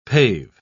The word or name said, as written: pave